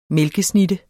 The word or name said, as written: mælkesnitte